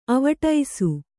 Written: ಅವ್ವಡಿಸು

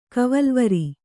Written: ಕವಲ್ವರಿ

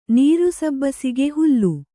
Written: ನೀರುಸಬ್ಬಸಿಗೆ ಹುಲ್ಲು

